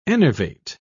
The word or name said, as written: enervate